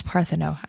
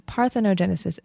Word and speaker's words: parthenogenesis: I said partho. It's partheno. I knew it. I thought it had a few more syllables. Parthenogenesis is a form...